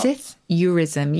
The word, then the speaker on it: psithurism: In fact, is the P pronounced? Should it just be Psithurism? – Well, you can pronounce it either way. That's a good point. Dictionary will give both.